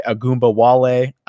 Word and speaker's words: Ogunbowale: And then I only want to try and say the other guy's name, but the pass catching running back, Dare Ogunbowale, I want to say is how you pronounce it.